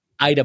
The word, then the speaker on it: Eidevall: Uh, please help me with the pronunciation. – Jonas Eidevall. Ball, Thank you.